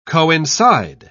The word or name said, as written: coincide